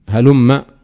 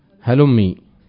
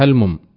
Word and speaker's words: هلم: وهلم هنا قد نبه بعضهم كابن مالك وغيره أنه بفتح الميم هلم، وحكى الجرمي الفتح والكسر عن بعض تميم، كيف سيكون النطق؟ ها؟ بالكسر هلمي. طيب، وذكروا أيضا فائدة أن إدغامه هنا لازم، فلا يفك، فلا يقال فيه هلمم، لا يقال فيه هلمم، قاله ابن مالك.